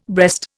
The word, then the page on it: breast: Indian